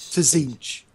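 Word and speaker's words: Tzeentch: But it's basically Slaanesh and Tzinch, which are the Tzinch. – I know. – Yeah. – You're a demon player. – Silent T, please. – Tzinch.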